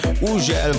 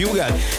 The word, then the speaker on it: Hugel: Így van, az úriember, akinek minden országban másképp ejtik a nevét, valahol Uzsel, van, ahol Hügel, van, ahol Ugel, meg mindenféle kép, de nálunk úgy mondjuk, hogy Hugel, és Salto és a Jamaican Bam Bam érkezik, a csavarjátok fel a hangerőt.